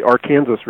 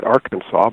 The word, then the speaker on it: Arkansas: which they call the Arkansas River. Other people would pronounce it Arkansas, but they have their pride in their way of saying it.